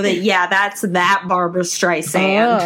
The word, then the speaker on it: Streisand: I really like her quote about it where she said, "It's Streisand with a soft S like sand on the beach.